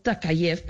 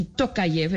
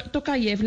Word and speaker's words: Tokayev: Esto, sin embargo, se volvió en unas protestas en contra del gobierno de Kazajistán y eh, el señor eh, Takayev, perdóneme que le diga a ver si Tokayev, estoy pronunciándolo bien,